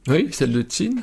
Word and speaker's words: Qin: Au départ, pratique, matériel, de ce petit état, peut-être du Wei, puis après de Qin, je ne sais pas très bien prononcer. – Oui, c'est ça, Wei et Qin.